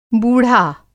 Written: बूढ़ा

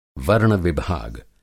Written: वर्ण-विभाग

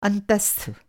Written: अंतस्थ

